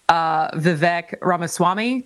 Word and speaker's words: Vivek Ramaswamy: And then obviously for Republicans, people who have announced so far are Nikki Haley, Vivek Ramaswamy. Did I pronounce that correctly? – That sounds correct, yes.